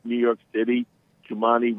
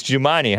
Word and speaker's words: Jumaane: Is that how you pronounce it? Jumani, J-U-M-A-A-N-E Williams. I thought it was Jumaine Williams, but it's Jumani, huh? – I guess so.